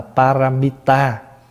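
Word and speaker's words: pāramitā: Cái chữ ba la mật này là tiếng Phạn, và chúng ta phát âm từ cái chữ Phạn là paramita. Và paramita thì chúng ta âm lại là ba la mật đa, và khi có khi gọi tắt là ba la mật.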